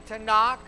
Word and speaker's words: Tanakh: The N, Tanakh, T-A-N-A-K, the vowels are put in there so we can pronounce it, is Nevi'im.